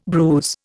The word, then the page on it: bruise: Indian